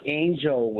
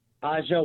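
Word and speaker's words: A'ja: Yeah, it's not Angel Wilson. It's Aja.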